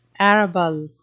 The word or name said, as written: arable